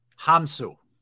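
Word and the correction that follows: Hounsou: It's pronounced Jaman. – Jaman Hansu. – Hansu.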